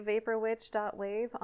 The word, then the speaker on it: Vaporwitch.wav: My name's Nicole, and I am the Vaporwitch.wav on Instagram. – Happy to... Wait, it's pronounced dot wave? – That's how I pronounce it.